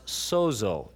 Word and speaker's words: sozo: One of his favorite words is a Greek word we would pronounce sozo, S-O-Z-O, which I'm told means both to save and to heal.